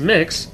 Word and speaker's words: Mx: And right thus far, I looked it up and discovered that MX, pronounced as mix, has been suggested.